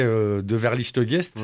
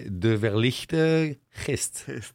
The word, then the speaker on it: De Verlichte Geest: Je vais le prononcer en flamand, mais à la française. Hein, je vais me faire engueuler. Hein. – Insulter, ouais. – En français, je dirais De Verlichte Geest. Ouais. Mais ça se prononcerait De Verlichte Geest.